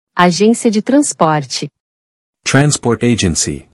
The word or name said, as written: Agência de transporte